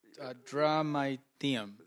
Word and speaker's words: Adramyttium: Adramyttium. – There you go. Perfect pronouncement. – Thank you. – That is incredible. – I went with the Greek pronunciation.